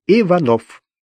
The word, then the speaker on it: Иванов: Иванов. С простонародным ударением на последний слог.